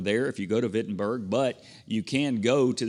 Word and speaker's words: Wittenberg: And that's the Reformation where Martin Luther nailed his 95 Theses on the church doors, the castle church doors there in Wittenberg. It's spelled Wittenberg with a W, but you pronounce it kind of in a Germanish way with a V.